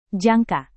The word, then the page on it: jangka: Indonesian